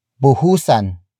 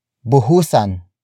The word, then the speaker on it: buhusan: Word for word, it's buhusan, natin, ng, tubig, ang, apoy. Buhusan, natin, ng, tubig, ang, apoy. Buhusan, natin, ng, tubig, ang, apoy. The first word is buhusan. Buhusan. Buhusan. Buhusan is spelled as B-U-H-U-S-A-N.